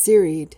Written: serried

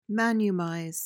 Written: manumise

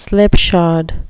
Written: slipshod

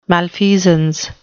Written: malfeasance

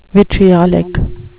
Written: vitriolic

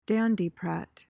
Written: dandiprat

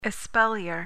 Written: espalier

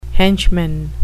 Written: henchman

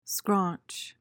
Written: scraunch

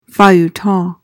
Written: feuilleton

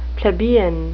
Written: plebeian

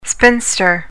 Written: spinster

